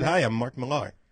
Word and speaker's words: Millar: Mark Miller, your friend. – Millar. – I don't think it's Millar. – It is pronounced Millar. – Where did you see it pronounced Millar? – On Newsarama. I actually heard him. – He said his own name and he said it was Millar.